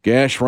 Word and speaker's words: Gash: Gash rhymes with dash.